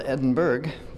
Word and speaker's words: Edinburgh: It's spelled Edinburgh, but pronounced Edinburgh.